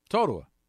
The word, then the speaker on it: Totowa: How do you pronounce T-O-T-O-W-A? – Totowa.